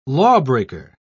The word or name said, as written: lawbreaker